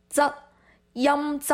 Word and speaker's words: 噪音: 那这个呢其实在粤语当中我们会发音里面就是有一个像一个滋滋的那个音就是噪噪音噪音噪是什么意思呢，